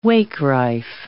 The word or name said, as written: wakerife